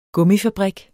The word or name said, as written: gummifabrik